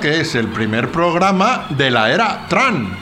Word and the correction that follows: Trump: ¿Se dice Trun o Tran? – Ah, Trump, Trump. Trump.